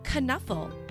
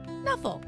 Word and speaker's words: Knuffle: Now, Mo Willems, the author of the book we are reading today, says that you can pronounce the word however you like, knuffle or knuffle. But for today, we are going to say the word knuffle because that's how Mo Willems himself pronounces it.